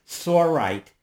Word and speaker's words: sorite: I'm not sure that I'm pronouncing this correctly, sorite. Am I? S-O-R-I-T-E? Or don't you know? – Yeah, I think that's right. It's more common to refer to it in the plural, right? The sorites.